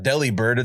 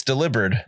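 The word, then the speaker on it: Delibird: It's not Deli Bird, it's Delivered. – Is it really? – Because he's a delivery bird.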